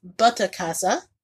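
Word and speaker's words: Butterkäse: This special cheese, which is pronounced butta casa, which, if you're not familiar, it's a German cheese.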